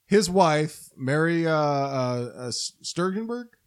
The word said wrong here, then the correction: Steenburgen: Yeah. I always mispronounce her name. Yeah, I'm very so sorry. You know, Back to Future 3. – Steenburgen? – Again, yeah.